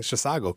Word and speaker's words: Chisago: Well, thanks for having me, and thank you for pronouncing Chisago correctly.